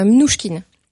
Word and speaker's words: Mnouchkine: Je ne sais pas si je le prononce bien. – Ariane Mnouchkine, oui. Mnouchkine.